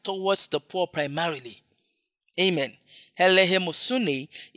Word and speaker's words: eleemosune: The phrase charitable deeds is from one Greek word, helehemosune. That's the Greek word, charitable deed. Helehemosune is spelled E-L-E-E-M-O-S-U-N-E.